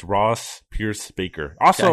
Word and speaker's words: Bacher: It's Pierce Baker, not Bacher. – Okay. – It's very tempting to say Bacher because it's spelled B-A-C-H-E-R.